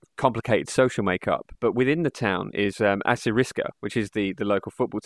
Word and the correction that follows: Assyriska: I mean, so it's pronounced Assyriska because an Assyrian person is Assyrisk in Swedish.